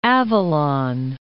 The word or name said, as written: Isle of Avalon